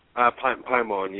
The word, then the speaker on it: Paimon: you had evoked the demonic being Paimon. Am I pronouncing that right? – Paimon, yes.